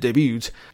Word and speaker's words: debut: 那这个东西是 debut in 2022，debut 是 D-E-B-U-T，它不是 debut，好，不是 debut，debut，而且那个 T 一般是不发音，那这边会发一个轻轻的的那个声音，是因为它加了 ed 啊。